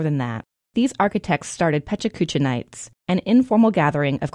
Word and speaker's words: pechakucha: This 20 by 20 method is known as pechakucha, or, closer to the proper pronunciation, pechachka.